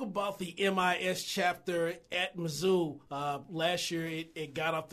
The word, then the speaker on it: Mizzou: Y'all Missouri people pronounce it like Mizzou, and I pronounce it Mizzou. So I'm pronouncing like y'all, Mizzou.